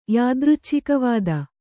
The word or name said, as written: ಯಾದೃಚ್ಛಿಕವಾದ